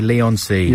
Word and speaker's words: Leigh-on-Sea: How do you pronounce that? – I'd say Leigh-on-Sea.